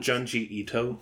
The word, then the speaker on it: Junji Ito: It's kind of like Junji Ito. Yeah, no, I'm Junji Ito.